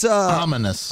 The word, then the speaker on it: ominous: I got this Omnius clock that you can look at. – Omnius. – How's the right way to say it? – But it's... – Ominous. – Ominous.